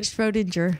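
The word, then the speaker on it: Schrödinger: Schrodinger.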